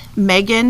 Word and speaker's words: Megan: My Twitter handle at Megan, MEGGINV, would be great.